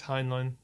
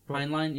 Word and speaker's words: Heinlein: Heinlein. – Yeah, Heinlein. I think it's Heinlein. – Heinlein.